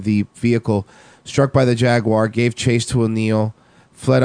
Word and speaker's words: Jaguar: Two officers had opened fire at O'Neill while he was still in the Jaguar, according to police. – It's pronounced Jaguar.